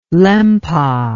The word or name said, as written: Lempa